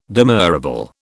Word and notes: demurrable: British English

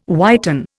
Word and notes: whiten: Indian

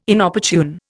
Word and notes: Inopportune: Indian

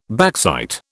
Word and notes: backsight: British English

